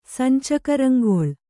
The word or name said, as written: ಸಂಚಕರಂಗೊಳ್